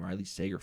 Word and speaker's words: Riley Sager: I believe he pronounces his name, Riley Sager, Final Girls.